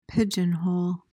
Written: pigeonhole

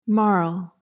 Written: marl

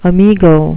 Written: amigo

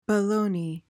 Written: baloney

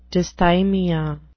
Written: dysthymia

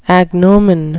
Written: agnomen

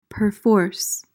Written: perforce

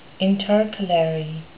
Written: intercalary

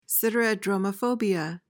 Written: siderodromophobia

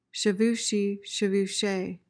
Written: chevachee

